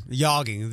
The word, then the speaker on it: yogging: Yogging. The J is silent.